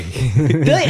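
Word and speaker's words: Willy: Billy, this is what his name is pronounced as. – Yeah, no one can really determine what it actually is, but I guess Billy means Willie in Spanish. – Well, I understand it, his I think his mother is German and something or other, they pronounce the W as a B.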